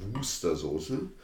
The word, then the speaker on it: Worcestersoße: Ihr wisst, Worcestersoße steht auf der Flasche. Ausgesprochen Worcestersoße. Ist eine Art Würzsoße, sehr britisch.